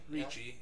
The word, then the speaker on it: Ricci: Christina Ricci, or Ricky, however you say her name. – Ricci, yeah.